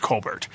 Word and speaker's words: Colbert: Because, I mean, let's face it, his last name is actually pronounced Colbert.